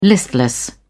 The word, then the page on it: listless: British English